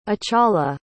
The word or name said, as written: achala